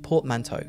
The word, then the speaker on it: portmanteau: I'm saying it, this is the English pronunciation, portmanteau.